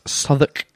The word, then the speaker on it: Southwark: Southwark is spelt Southwark. – Pronounced Southwark. Spelt Southwark. – Spelt Southwark.